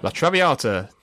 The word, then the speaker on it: La Traviata: Number 9, La Traviata.